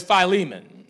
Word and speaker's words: Philemon: That's one of the books of the Bible that I just butcher, Philemon, but it's Philemon. All right, put a long I sound and a long E sound on, and I believe you'll have it.